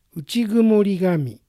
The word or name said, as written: Uchigumorigami